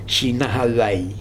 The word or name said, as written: jinahavae